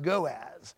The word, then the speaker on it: goes: That word seducers comes from the Greek word goas, and it's spelled like G-O-E-S, spelled out, but it's pronounced goas.